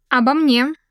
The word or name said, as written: about me